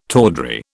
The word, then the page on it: tawdry: British English